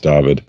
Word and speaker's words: Dyfed: It's spelled D-Y-F-E-D, but it's Welsh, so it's pronounced David.